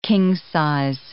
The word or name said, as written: king-size